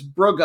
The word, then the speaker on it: Brugge: I think it's Brugge. I don't think it's a soft G like it would be for Bruges, the city.